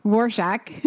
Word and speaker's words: Rorschach: Rorschach. Right? – Yeah. Rorschach. Saying, right? Yeah. Rorschach. – Okay. Because it's not spelled the way it's pronounced at all.